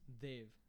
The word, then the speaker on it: Dev: So in India, the actual name, so D-E-V, is pronounced Dave. So like T-H-E-Y-V-E.